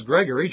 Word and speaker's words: Gregory: it is spelled G-R-E-G-G, Gregory, I mean Greg, as opposed to Gregory, but my name is Gregory, G-R-E-G-O-R-Y, but when I use the shortened form, of course, I spell mine G-R-E-G,